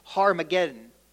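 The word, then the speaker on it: Har Megiddo: And it's derived from the original Hebrew word that's pronounced Harmageddon is where it comes from.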